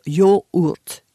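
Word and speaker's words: yoğurt: En turc, on écrit le mot yogurt, mais on le prononce yaourt, donc plus proche de yaourt.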